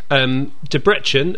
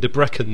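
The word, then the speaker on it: Debrecen: De Brechen, who I'm now calling by their proper pronunciation, I was calling De Brecken the last time we played against them.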